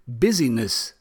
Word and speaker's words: busyness: I always have difficulties pronouncing that word, as it sounds like you're incorrectly pronouncing business, but I really mean busyness, the degree that a shop or another place on maps is busy or crowded, if you want.